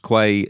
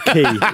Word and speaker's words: Quay: Forgive pronunciation, but I'm pretty sure it's Connors Quay Nomads. – It's definitely not Quay, is it? – Connors Quay. – Yeah. – Like, you know that word.